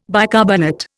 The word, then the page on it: bicarbonate: Indian